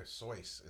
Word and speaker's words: Seuss: Dr. Seuss is how you say the name.